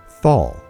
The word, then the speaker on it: Thal: The old German word for valley was Tal or Thal with a silent H.